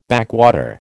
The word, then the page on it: backwater: American English